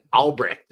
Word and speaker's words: Albrecht: You said Albrecht is how you pronounce his name, right? – Yep.